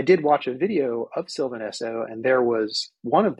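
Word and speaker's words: Sylvan Esso: And one of them is part of, and I'm going to mangle this, but Sylvain Esso, I think that's how it's pronounced. – Oh, Sylvain Esso. Yeah, yeah.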